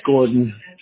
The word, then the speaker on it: Gordon: If you want the official name, it's Gordon, G-O-R-D-O-N.